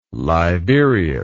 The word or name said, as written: liberius